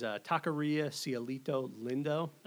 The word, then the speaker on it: Taqueria Cielito Lindo: But right next to there is Taqueria Cielito Lindo. – Okay. – Or at least that's how this gringo pronounces it. – Yeah, sure. – It sounded actually pretty good there.